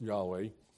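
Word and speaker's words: Yahweh (יהוה): Actually, the Hebrew doesn't even have any vowels. But it is pronounced Yahweh.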